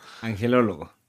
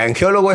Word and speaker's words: angelólogo: Angelólogo. – Angelólogo. Ok, sí, porque angeólogo es otra cosa.